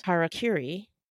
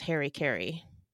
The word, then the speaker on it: harakiri: Honor suicides are also referred to as harakiri. which Westerners often mispronounce as Harry Carey.